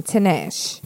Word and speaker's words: Tinashe: I don't even know how to say her name. Tinashe. T-I-N-A-S-H-E.